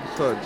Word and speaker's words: Todd: Todd. – Just for a record, yeah? – Todd. – Safe, man. Yeah. And how'd you spell that? – T-O-D-D.